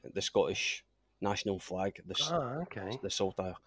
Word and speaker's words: Saltire: So if anyone actually wants to find you, I'll put because your race team is, how do you pronounce that? Is it Saltire? – Saltire. So the Scottish Saltire. It's a flag. – Saltire.